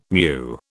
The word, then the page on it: mu: British English